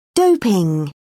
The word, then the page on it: doping: British English